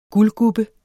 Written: guldgubbe

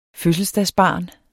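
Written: fødselsdagsbarnet

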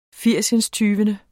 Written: firsindstyvende

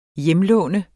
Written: hjemlån

